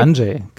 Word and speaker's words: Andrzej: Ja, Andrzej. Andrzej, genau. Ja. Andrzej Sapkowski hat äh, gefühlt ich glaube, es gibt vier Bücher und, eine, und drei Sammlungen von Kurzgeschichten.